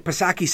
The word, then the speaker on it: Psaki: Then you've got Jen Pacircleback Psaki, Silent P, of course.